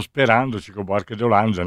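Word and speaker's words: Chico Buarque de Hollanda: Di quelli tosti, anche perché sperando, Chico Barche de Olanda, no? E nei cantieri. Visto... – Ma come lo pronunci bene, di Olanda?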